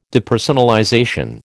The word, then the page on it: depersonalization: American English